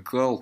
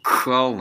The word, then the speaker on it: Khal: Ну, мне тут поправляют, чтобы Кхал на самом деле зовут, но это неправда, потому что его зовут Кал. Потому что Khal читается как Кал. Вот, и в сериале его все называют Кал, называли.